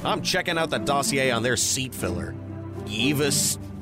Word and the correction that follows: Iavos: Isidori. – Iavos Isidori. There's a pronunciation guide on every sheet, Dirk.